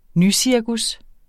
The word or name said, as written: nycirkus